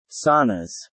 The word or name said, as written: saunas